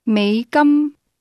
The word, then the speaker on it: 美金: It's pronounced with a low tone that rises to a high level. Listen again and repeat. – 美金 Say, I have 14 American dollars, or 14 units U.S.